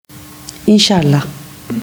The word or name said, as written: inshallah